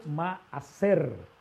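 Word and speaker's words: ma'aser (מעשר): que en hebreo se pronuncia ma'aser, que quiere decir rescate, que quiere decir algo que te ofrende en espera de una bendición mayor.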